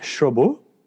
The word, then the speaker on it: Cherbourg: And then I watched The Umbrellas of Cherbourg.